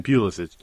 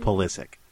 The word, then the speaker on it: Pulisic: By the way, I pronounce it the way you did because I'm a sheep, but it's pronounced Pulisic. Say it with me. – Pulisic. That's just wrong. That's not... It's Pulisic. That's no, it's not. – Clearly the right... – Yeah, I just can't stress enough that it's not. And if I know how to pronounce this name... – Pulisic? – Pulisic. – So the stress is on the second syllable, so correct. It's almost like saying ballistic? – Yes.